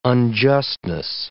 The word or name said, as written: unjustness